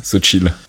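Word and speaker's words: Xochitl: Je son nom à prononcer, c'est Sochi, je crois, ça se prononce Sochi Gomez. – Ouais, c'est ça, Sochi.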